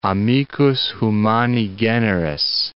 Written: amicus humani generis

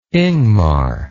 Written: ingmar

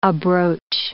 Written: abroach

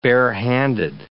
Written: bare-handed

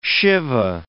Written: Shiva

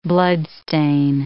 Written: bloodstain

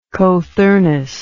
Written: cothurnal

